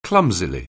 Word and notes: clumsily: anglais

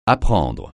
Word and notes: apprendre: francais

